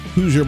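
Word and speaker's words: Hooser: Our podcast, The Voice of Reason with Andy Hoosier. Hoosier, by the way, spelled with no I, H-O-O-S-E-R.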